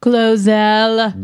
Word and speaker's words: GloZell: Glozell.